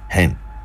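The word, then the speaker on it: hen (חֵן): Now we go to the other one, which is the Hebrew word, and that word is hen, H-E-N, hen, and pronounced thus.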